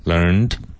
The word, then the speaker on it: learned: Where was he when he learned, one syllable, learned.